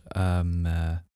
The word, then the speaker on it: Mahé: which is the Mahé, I believe it's pronounced. – Mahé.